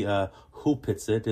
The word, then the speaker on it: Opetset: It's written Opetset, but it's actually pronounced properly Hupetset.